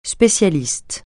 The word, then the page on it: spécialiste: francais